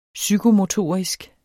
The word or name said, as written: psykomotorisk